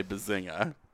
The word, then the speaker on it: bazinga: I did say bazinga. – Did you pronounce the G? – Wow. – No, soft G on that one.